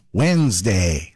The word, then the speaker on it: Wednesday: It's midweek Wednesday, which of course we pronounce Wednesday. So why are we spelling it with a random D that nobody invited to the conversation? English is full of words like that. Take Colonel, for example. It's spelled C-O-L-O-N-E-L, but pronounced Colonel.